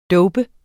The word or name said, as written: dope